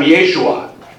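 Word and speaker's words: Yeshua (ישוע): Jesus is how we in English say what was probably pronounced at the time, Yeshua.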